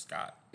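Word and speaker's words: Schott: I believe it's actually pronounced Scott.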